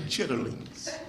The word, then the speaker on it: chitterlings: When I was a boy, my mother used to cook chitlins for us on occasion. You know what chitlins are, don't you? For those of you who are sophisticated, you pronounce them chitterlings.